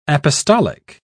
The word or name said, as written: apostolic